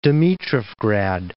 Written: Dimitrovgrad

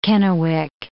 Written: Kennewick

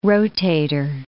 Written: rotator

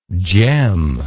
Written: gemlike

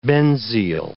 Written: benzyl

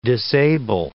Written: disable

